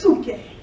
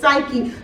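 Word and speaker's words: psuche (ψυχή): That word is psuche. Like I said, we've been commonly pronouncing it psyche.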